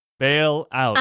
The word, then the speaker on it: bail out: Este Bill, ¿cómo se dice? Bill out. – de nuevo. Bail out.